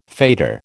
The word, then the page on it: fetor: American English